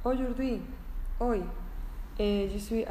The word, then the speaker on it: aujourd'hui: pero ellos ponen A-U-J-O-U-R-D apóstrofe H-U-I. Fijaros, para escribir nuestro hoy, todas las letras que ponen se pronuncia aujourd'hui, hoy. Y mañana se dice demain, e, D-E-M-A-I-N,